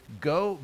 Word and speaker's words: Geaux: Anyone who still wishes to vote in the November elections can do so by going to the state's Go Vote online registration system. That's spelled G-E-A-U-X, vote.